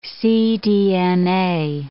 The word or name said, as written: cDNA